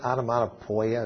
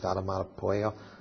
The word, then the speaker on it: onomatopoeia: I think that was that term that was used, onomatopoeia. I'm saying it wrong. Onomatopoeia. Okay, pia.